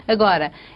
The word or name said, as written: agora